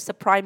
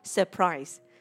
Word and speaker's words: surprise: Các bạn đừng đọc là surprise các bạn nha. Tại vì rất là nhiều những bạn học viên trong lớp, các bạn cứ surprise, surprise. Nhưng mà từ đây mình đọc là surprise. Surprise. Các bạn nghe ha.